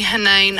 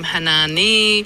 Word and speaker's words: Hanan: Hanan. – I get called variations of my name. Hanani, Hanan. It's just Hanan. It's a silent E because I'm extraordinary.